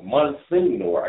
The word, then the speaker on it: Monsignor: Monsignor, I guess that's the pronunciation of it. It's Italian. It's Italian.